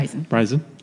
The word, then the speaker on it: Rizin: Rizen. – Like Rizen Sun, but yeah. It's spelled R-I-Z-I-N. – Oh, R-I-Z-I-N. Okay. – Yeah, but it is like rising, you know, like.